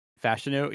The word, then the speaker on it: Fashanu: I ended up taking Olu Fashanu. Is that how you pronounce it? Is it tackle? – Fashanu. Yeah, I like how we both just went with Olu instead of his full name, which I think Oluyamid, maybe?